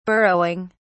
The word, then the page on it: burrowing: British English